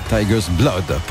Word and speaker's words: Tiger's Blood: Plutôt Tiger's Blood, pour bien prononcer à l'anglaise.